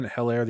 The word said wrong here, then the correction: Edwards-Helaire: the entire time. Apparently it's Clyde Edwards-Helaire. The H is silent, so okay.